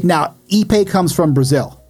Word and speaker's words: Ipe: IP. – No, Ipe. – Ipe? That's how you say it.